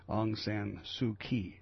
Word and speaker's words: Aung San Suu Kyi: Aung San Suu Kyi is how you say it, is 67.